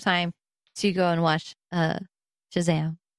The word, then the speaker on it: Shazam: Shazam! – What's going on? – Say it correctly. There's an exclamation mark up there. You gotta say it with the oomph. You gotta be Shazam!